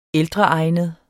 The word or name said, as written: ældreegnet